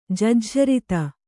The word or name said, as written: ಜಜ್ಝರಿತ